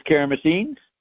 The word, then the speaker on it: Karamessines: K-A-R-A-M-E-S-S-I-N-E-S. – Okay, I got Kara. K-A-R-A-M-E-N-S? Where it got lost after M-E? – M-E-S-S-I-N-E-S.